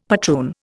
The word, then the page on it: patroon: Indian